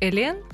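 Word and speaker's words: Hélène: Ну вот да, просто на французском мое имя пишется как Элен. И через «х», которое не читается во французском.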